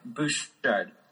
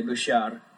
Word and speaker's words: Bouchard: Bouchard. – Bouchard. – But my French relatives would say Bouchard.